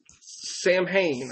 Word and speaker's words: Samhain: Their pay-per-view for the month of October, and you'll never know it by the way it's spelled, but it's Samhain or something like that.